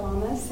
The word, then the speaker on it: Lamas: My name is Trisha Lamas, and I live here in Bakersfield.